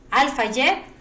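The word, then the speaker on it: Al Fayed: Y estaba embarazada de este Dodi, Lafayette. Alfayette siempre lo he dicho mal, discúlpenme. Alfayette Y, y con unos este.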